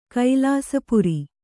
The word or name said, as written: ಕೈಲಾಸಪುರಿ